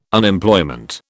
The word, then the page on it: Unemployment: British English